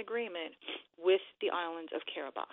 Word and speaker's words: Kiribati: It's spelled Kiribati, but it's pronounced Kiribati.